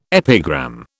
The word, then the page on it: epigram: British English